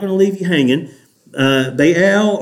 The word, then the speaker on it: Baal: Okay, how do you say, somebody tell me, how do you say B-A-A-L? Baal. Okay, because I've heard it in two syllables. Baal. So I'm from the South, and we like to put as much syllables in a word as we can, but I can't get two out of Baal, all right?